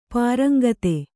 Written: ಪರೇಂಗಿತ

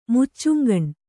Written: ಮುಚ್ಚುಂಗಣ್

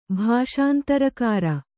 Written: ಭಾಷಾಂತರಕಾರ

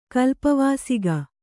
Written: ಕಲ್ಪವಾಸಿಗ

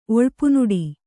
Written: ಒಳ್ಪುನುಡಿ

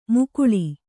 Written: ಮಖಾಳ

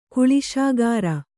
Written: ಕಲ್ಲುಸಕ್ಕರೆ

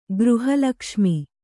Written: ಗೃಹಲಕ್ಷ್ಮಿ

